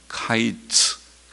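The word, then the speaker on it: 카이츠 (קיץ): 여름 실과, 우리말에는 그냥 실, 여름 실과라는 말고 이스라엘의 끝이라는 말이 전혀 다른 단어이지만은 그러나 히브리어에서 여름 실과라는 단어는 카이츠 그렇게 발음을 하는데 카이츠라는 이 말은 이스라엘의 끝이라는 히브리어 단어가 캐츠 그럽니다.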